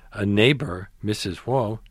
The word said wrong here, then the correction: Hua: am I pronouncing that right? – Hua, repeatedly asked the boy's mother for donations in support of anti-communist guerrillas back home.